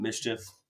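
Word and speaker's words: MSCHF: Um, I think the brand, I think you pronounce it Mischief.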